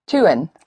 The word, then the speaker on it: tun: To do, but the D of do becomes a T, and you have? – To. – To do. – Tun. – Yes. Tun became actually Tun. It's spelled T-U-N. Tun.